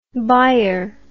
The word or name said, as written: Bayer